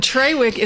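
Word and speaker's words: Traywick: And Trawick is not a mispronunciation.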